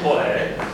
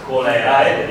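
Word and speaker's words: cholerae: Biblio colere, colerae, vedete, si scrive così perché è colere, si scrive colerae, non si pronuncia colera.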